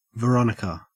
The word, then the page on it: veronica: Australian English